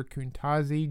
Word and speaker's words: Quntazi: In 2021, Potosi Officer Kuntazi Jones, that's spelled Q-U-N-T-A-Z-I, Jones, was charged with 7 counts of statutory rape involving a girl younger than 17 years old.